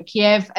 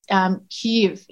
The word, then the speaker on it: Kyiv: Kiev, as I understand it, was the Russian pronunciation, and it's Kyiv in Ukrainian.